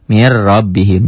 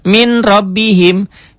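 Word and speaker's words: Mir rabbihim (من ربهم): Min jadi mir. Mir rabbihim.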